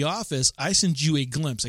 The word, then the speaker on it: Glympse: And that app, rather, is called Glimpse. And it's spelled G-L-Y-M-P-S-E, Glimpse.